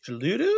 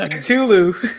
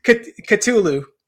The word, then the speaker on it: Cthulhu: Is it it's Chaludu, or do you know how to say it? – It's Cthulhu. – Oh, it's what? – Cthulhu.